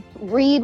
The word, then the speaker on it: Reid: And then Reed was a little different. I liked kind of playing around with the word Reed. You know, his name is spelled R-E-I-D, but it's a homonym for reading.